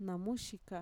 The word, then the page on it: namuxika: Mixtec